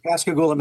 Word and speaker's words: Pascagoula: Her name is Irina, and she's going to talk to us about the Pascagoula abductions. Is that right? Did I pronounce that right? – Yeah, Pascagoula, Mississippi.